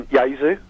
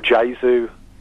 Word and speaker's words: Jesu: Yeah, it's called Jesu, or Jesu. It can be pronounced either way, but it's J-E-S-U.